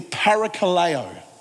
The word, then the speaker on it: parakaleo: When the writer of the Hebrews said, encouraging one another, the word encourage comes from a Greek word that when we pronounce it, it is parakaleo.